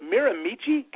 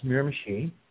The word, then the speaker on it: Miramichi: Is it Miramichi, Canada? – No, it's actually pronounced Miramichi. It is an Indian name or First Nation, as we call them in Canada.